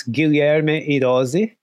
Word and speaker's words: Guilherme: I mean, yeah, kind of like, he's in Portuguese, it's Guilherme Heróis. – Okay, But Gil or Guy works.